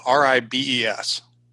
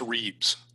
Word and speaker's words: ribes: Um, and in the literature, it's spelled R I B E S, mm-hmm. – uh, which I pronounce Reeves. Okay. Uh, we got an email from Peter Weishample, Weishample uh, who has a correction. Okay. – Oh, so I'll read it now. – It's pronounced ribes, as in the genus name for currants, which are sometimes called gooseberries.